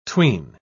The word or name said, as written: tween